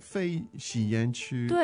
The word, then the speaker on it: 非吸烟区: Now, 区, they're all first tones, aren't they? 非吸烟区。 – 对哦。 – Okay, wow, that's four firsts there.